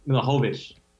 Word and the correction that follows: Mihailovic: Mihailovic. Uh, Mihailovic. This is the guy that uh,